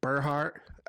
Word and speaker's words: Burghardt: William Edward Burghardt. I looked it up. That is how you pronounce it.